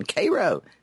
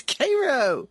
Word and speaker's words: Cairo: It is, and I purposefully go with the South Georgia pronunciation, Cairo. It's Cairo. – I fully approve of Cairo. C-A-I-R-O.